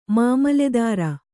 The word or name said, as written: ಮಾಮಲೇದಾರ